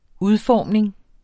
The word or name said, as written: UDFORMNINGEN